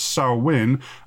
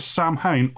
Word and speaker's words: Samhain: It looks like it's spelt as Samhain, but it's pronounced Samhain, as the native languages of Albion aggressively resist English.